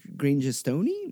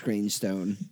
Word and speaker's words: Grangestone: Grangestone, which I was looking at it tired the other day, and I'm like, how do you pronounce this? Grangestone? No. Grangestone-y? No. It's just Grangestone.